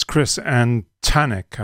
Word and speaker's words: Antonik: So that's Chris Antonik, I'm told, is uh, the way it's supposed to be pronounced.